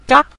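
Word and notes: ga: tone 5

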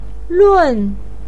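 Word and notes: lun4: pinyin; tone 4

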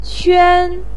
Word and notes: quan: 1

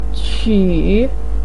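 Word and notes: qu3: tone 3

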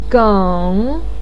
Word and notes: geng: 3

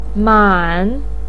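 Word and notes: man3: pinyin; tone 3

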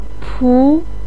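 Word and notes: pu2: tone 2

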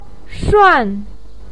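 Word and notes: shuan: tone 4

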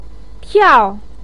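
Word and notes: tiao: tone 4

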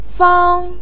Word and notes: fang: 1

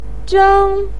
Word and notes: zheng: 1

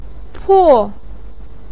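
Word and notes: po4: tone 4

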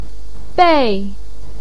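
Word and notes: bei4: tone 4

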